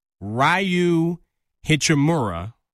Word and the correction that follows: Rui Hachimura: Rui Hachimura.